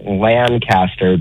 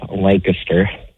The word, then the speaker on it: Lancaster: And I know you Californians say it Lancaster, but it's actually pronounced Lancaster.